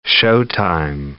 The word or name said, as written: showtime